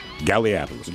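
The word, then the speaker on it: Gallipolis: Galliapolis, G-A-L-L-I-P-O-L-I-S. I believe it's Galliapolis.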